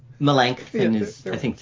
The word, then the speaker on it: Melanchthon: But Melanchthon, how do you pronounce that name? Melanchthon? – You can. Melanchthon is, I think, the typical English.